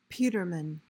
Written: peterman

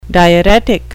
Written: diuretic

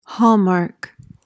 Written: hallmark

